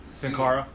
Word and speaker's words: Sin Cara: How was the Cena, Cien? – Sin Cara.